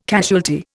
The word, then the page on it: casualty: Indian